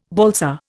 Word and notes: balsa: Indian

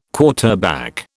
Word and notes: quarterback: British English

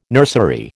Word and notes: nursery: American English

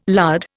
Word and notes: lard: Indian

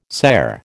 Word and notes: cere: American English

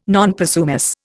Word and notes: non possumus: Indian

